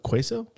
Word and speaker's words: queso: Say it right once. – What, queso? Quesos? – Yeah, that's how it is.